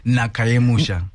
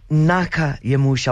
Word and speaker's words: Naka Yemusha: Is it Naka? – Naka Yemusha. – Naka Yemusha. I wanted to get the pronunciation right.